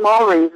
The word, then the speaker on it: Maori: They're not Maoris, they're Maoris. – Oh, okay. – I used to live in New Zealand, so I know it's Maori, M-A-O-R-I, Maori.